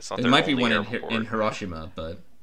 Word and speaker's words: Hiroshima: It's pronounced Hiroshima.